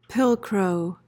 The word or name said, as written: pilcrow